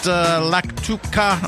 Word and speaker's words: lactuca: The word lettuce was derived from the Latin term lactuca, pronounced lactuca. I pronounced it right.